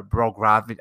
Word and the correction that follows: Bogdanovich: I can't even say his name, not Brogdon, Bo- Bogdanovich, and you have um, Mike Conley, who's out of a bubble for the birth of his child.